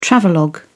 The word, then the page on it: travelogue: British English